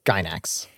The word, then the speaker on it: Gainax: Gainax.